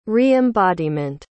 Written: re-embodiment

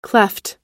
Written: klepht

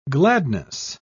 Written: gladness

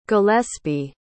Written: gillespie

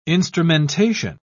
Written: instrumentation

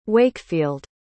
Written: Wakefield

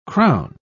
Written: crown

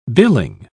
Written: billing

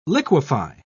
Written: liquefy

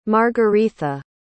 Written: margaretha

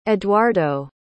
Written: eduardo

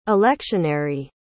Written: electionary

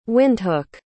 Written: Windhoek

